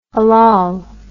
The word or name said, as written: hallal